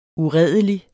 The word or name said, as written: uredelig